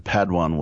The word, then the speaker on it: Padawan: And Padawan was correct.